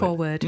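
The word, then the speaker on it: Industry4WRD: I think it's pronounced Industry Forward. – Forward, yes.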